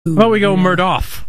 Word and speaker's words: Murdaugh: They pronounced it Murdaugh, right, down there? – Yeah. – The family pronounced it Murdaugh, but everybody else was Murdaugh.